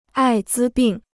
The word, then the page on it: 艾滋病: ài zī bìng